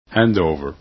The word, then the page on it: handover: english